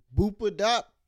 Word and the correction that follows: Bapadada: Bapadada. Yeah, Bapadada.